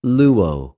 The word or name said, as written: λυω